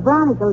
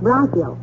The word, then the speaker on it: bronchial: You heard Doc Gamble tell me if I didn't cut out cigars, my chronicle tubes would get stopped up and I wouldn't... – The word is bronchial, dearie, and it's pronounced bronchial.